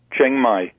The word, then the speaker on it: Chiang Mai: Let's listen to Chiang Mai Drift. Is that how you say it? – Chiang Mai. Chiang Mai Drift.